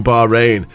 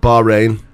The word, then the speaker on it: Bahrain: Because you miss the H. You go Bahrain. Like you sound B-A-R.